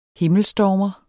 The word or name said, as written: himmelstormer